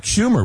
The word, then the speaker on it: Schumer: I believe that was Chuck Schumer, was it not? – Yes. Yeah. The senator's name rhymes with rumor.